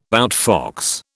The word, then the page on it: outfox: British English